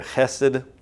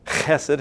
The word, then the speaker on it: chesed: The loyal love there is the word chesed. Chesed, if you say it right, you kind of, something comes out of the back of your throat.